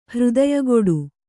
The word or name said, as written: ಹೃದಯಗೊಡು